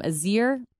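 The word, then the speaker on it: Ezer: I believe you pronounce her name Azir. It's E-Z-E-R, Billy White.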